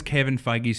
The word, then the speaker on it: Feige: As long as Kevin Feige's still at the helm, then yes. – Is that how you say his name? – Kevin Feige. Yeah.